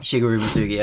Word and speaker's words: Shigeru Mizuki: Yeah, because today we are covering Shigeru Mizuki. Did I say that correctly? – Shigeru Mizuki, yeah.